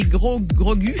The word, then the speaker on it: Grogu: Je vous dirais que c'est Star Wars, le Mandalorian et Grogu.